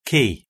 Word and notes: key: British English

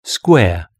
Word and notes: square: British English

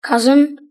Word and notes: cousin: British English

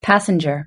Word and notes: passenger: British English

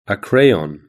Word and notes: crayon: British English

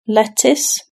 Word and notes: lettuce: British English

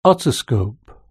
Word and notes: otoscope: British English

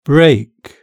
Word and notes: brake: British English

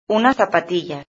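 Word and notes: una zapatilla: Spanish (Spain)

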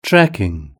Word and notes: trekking: British English

